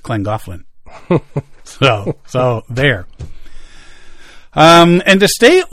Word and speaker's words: Llangollen: But I do like how he put the name in North Wales pronounced Llangollen, because that's not how it looks.